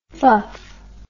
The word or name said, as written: fuff